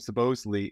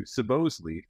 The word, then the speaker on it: Sobosley: And yes, we're saying my second name, Sobosley, is a Hungarian name, originally pronounced Szoboszlai. And when my grandparents emigrated to the U.S., they changed the pronunciation to Sobosley, feeling that it was more American sounding.